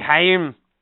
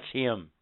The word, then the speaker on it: hymn: Haim. What? Mihaim. It's spelled H Y M N. H Y M N. That's him. Him. You know, as in, oh, we're singing a hymn.